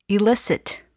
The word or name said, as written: elicit